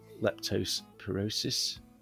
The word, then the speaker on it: leptospirosis: It was eventually discovered that he had contracted an infectious disease called leptospirosis. I think that's how you say it. – Well said.